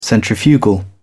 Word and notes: centrifugal: British English

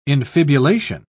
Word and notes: infibulation: British English

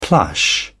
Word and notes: plush: British English

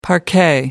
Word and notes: parquet: American English

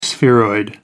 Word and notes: spheroid: American English

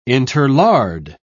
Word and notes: interlard: British English

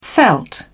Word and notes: veld: British English

